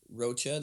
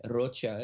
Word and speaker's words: Rocha: My last name is Rocha. That's an anglicized way to say it. Rocha is the correct way to say it. It's not actually Hispanic in that it doesn't have its origins in the Spanish language, although it is Iberian. It's Portuguese.